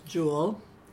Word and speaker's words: Jewell: My name is Eunice Jewell. Jewell is spelled J-E-W-E-L-L.